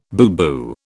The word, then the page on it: booboo: British English